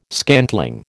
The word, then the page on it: scantling: American English